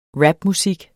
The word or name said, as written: rapmusik